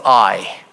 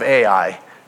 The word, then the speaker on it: Ai: Ai. Some of you may understand it better as AI. Okay, so they said that archaeologists found the city of Ai, it's pronounced Ai.